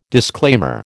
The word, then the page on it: disclaimer: American English